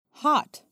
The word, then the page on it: hot: American English